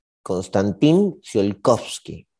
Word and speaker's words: Konstantin Tsiolkovsky: el físico se llamaba Konstantin Tsiolkovsky. Konstantin Tsiolkovsky. Sí, lo pronuncié bien, estoy bien.